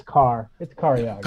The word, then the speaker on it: Cariaga: Yeah, I mean, everybody says Cari. It's Cariaga.